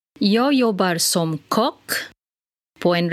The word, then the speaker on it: kock: It is spelled K-O-C-K. Kock. It is important to take note of this. So the word is spelled K-O-C-K. So the O in this word is followed by the two consonants C and K, which makes the O sound short. Like in the English word stock, lock, or dock, as in docking, D-O-C-K-I-N-G.